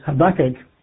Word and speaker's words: Habakkuk: Today, we're going to begin a Bible study of the book of Habakkuk, or if you prefer, Habakkuk, but I think the preferred pronunciation is Habakkuk.